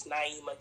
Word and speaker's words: Nyemah: My name is Naima King. It's spelled N-Y-E-M-A-H King, like Martin.